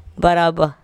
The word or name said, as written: mat-baraba